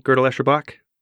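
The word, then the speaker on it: Gödel, Escher, Bach: And could you just pronounce GEB, Gödel-Escherbach? – Gödel-Escherbach. You got it.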